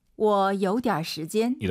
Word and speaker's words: 我有点时间: 我有点时间。 – 삼성이 연달아 세개 있어요. 근데 주어부터 삼성이에요. 그러면 무조건 아니지만 보통 이 삼성이 반삼성입니다. 워 올라가는 게 아니라 우리 왕 선생님처럼 워 요디알 들어볼게요. – 我有点时间